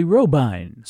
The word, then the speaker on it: Robyn: This time you heard a vocal version, also on Cameo, number 396, made July 29, 1923, sung by Willie Robine, spelled R-O-B-Y-N, but pronounced Robine.